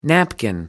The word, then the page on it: napkin: American English